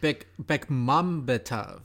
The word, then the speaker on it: Bekmambetov: I think I actually pronounced that correctly. – I think you got Bekmambetov.